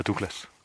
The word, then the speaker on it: Douglas: Nej, det er derfor, han hedder Douglas. – Det, det er det. Det er derfor. – Ah. Det er ikke fordi, at man udtalte engelsk dårligt dengang. Det er simpelthen den korrekte skotske udtalelse.